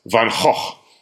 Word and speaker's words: Van Gogh: Yes, Van Gogh.